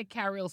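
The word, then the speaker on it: Carryl: Don't say Carrill because then it's people think it's going to be spelled differently. – No, because it's C-A-R-R-Y-L. So I say Carrill so people know how to spell it. – Okay. – It's C-A-R-R-Y-L.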